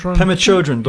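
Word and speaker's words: Pema Chödrön: There was this period in our lives where we were reading, I never know how to pronounce her name, Pema Chodron. – Pema Chodron, Buddhist, Tibetan Buddhist teacher.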